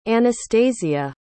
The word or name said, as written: Anastasiya